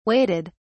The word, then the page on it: waited: British English